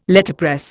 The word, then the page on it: Letterpress: Indian